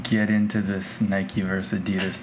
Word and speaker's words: Adidas: It's pronounced Adidas, by the way. You Americans, you damn Americans pronouncing shit wrong. Adidas.